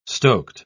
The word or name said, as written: stoked